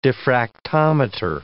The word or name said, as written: diffractometer